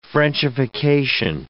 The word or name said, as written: frenchification